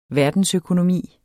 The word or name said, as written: verdensøkonomi